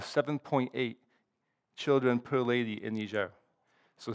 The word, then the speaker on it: Niger: It's called Niger. English pronunciation could be Niger.